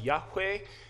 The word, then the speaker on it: Yahweh: When you see the Lord in all caps in English translations, that's the tetragrammaton, four letters, YHWH. It's pronounced Yahweh, but the Old Testament Jewish people, in order to never say God's name in vain, they just didn't say it.